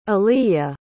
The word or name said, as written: aliya